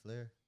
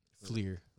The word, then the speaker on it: Fleer: How do you pronounce that name? Flair? – Fleer.